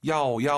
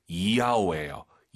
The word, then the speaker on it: 要: YAO 발음할 때 한국인들이 보통 야오 야오 하시는데요. 틀린 발음이에요. Y를요, 항상 I로 바꾸세요. 정확히 발음하면은요, 이야오예요. 이야오.